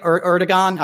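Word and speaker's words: Erdoğan: Erdogan. I always say his name wrong. It's not a hard G.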